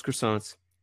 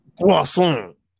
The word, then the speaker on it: croissant: And they were eating their McDonald's croissants, and I was there. – Pronounce it correctly. – Croissant. – It's like French. – There you go. – French. Croissant.